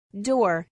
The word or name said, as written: door